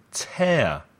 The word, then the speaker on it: tear: Be careful with the word tear because the spelling, T-E-A-R, is the same as the verb to tear, arrancar. Listen to the difference in the pronunciation. Tear and tear.